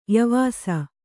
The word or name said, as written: ಯಾವಸ